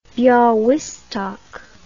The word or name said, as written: Bialstok